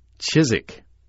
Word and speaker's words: Chiswick: it would always be pronounced Chiswick," the W being quite silent.